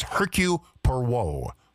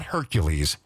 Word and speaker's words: Hercule Poirot: And by the way, do you know how to say his name? It's Hercule Poirot. Hercule Poirot. That's how they say it. – Thank you. – It's not Hercules.